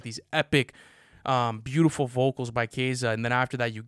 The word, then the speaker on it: Kiesza: Yeah, but it's not spelled like Kesa. – How's it spelled? – K-I-E-S-Z-A.